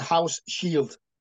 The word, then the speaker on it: Hauschild: because we're speaking to Michael Housechild. I hope I pronounced your name, Michael. Happy New Year. How are you? – Happy New Year, Phil. I'm good, thank you. But it's like a house shield.